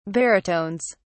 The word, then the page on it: baritones: British English